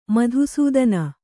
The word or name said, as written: ಮಧುಸೂದನ